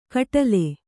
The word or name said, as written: ಖಟ್ಲೆ